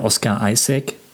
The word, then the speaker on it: Oscar Isaac: Auch hier der Oscar Isaac, wird er so ausgesprochen? – Würde ich auch so machen, ja.